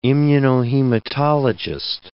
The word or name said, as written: immunohematologist